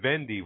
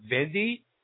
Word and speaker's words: Wendy: Only she pronounces it Vendy with a V, because that's how you pronounce the W in Holland.